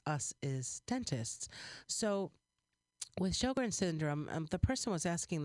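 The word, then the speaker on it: Sjögren's: Sjogren's, it's spelled S-J-O-G-R-E-N, but it's pronounced like show your grin, Sjogren.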